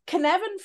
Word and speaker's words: Cynefin: Okay, and the spelling of it is Cynefin, pronounced Cynefin, and as you said, it's a Welsh word, and it means sort of habitat, accustomed, familiar. And the Cynefin framework is a framework used to help decision making.